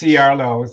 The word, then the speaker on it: Ciarlo: Oh, it's Ciarlo.